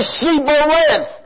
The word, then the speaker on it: Shibboleth: And so they said Sibboleth. They could not pronounce the H.